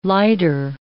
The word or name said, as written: lighter